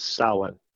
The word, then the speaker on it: Samhain: Samhain, it's not pronounced Samhain. If anyone says that, please stop saying that. It's Samhain. It's Irish, okay? It's an old word. Irish spelling does not make sense with their pronunciations.